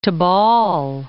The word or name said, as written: Tobol